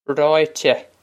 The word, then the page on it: Ráite: Raw-ih-teh